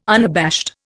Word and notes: unabashed: Indian

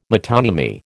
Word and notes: metonymy: American English

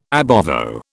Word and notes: ab ovo: British English

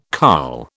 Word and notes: carl: British English

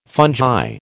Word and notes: fungi: American English

